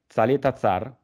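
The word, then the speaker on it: Çalhanoğlu: Infatti dopo vorrei un po' parlarne, anche perché poi c'è stato Southampton-Chelsea, quindi la pronuncia esatta è Calhanoglu? – Dovrebbe essere, sì, wow, l'ho sempre chiamato così, l'ho sempre sentito così, però non...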